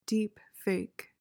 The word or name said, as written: deepfake